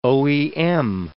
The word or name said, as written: OEM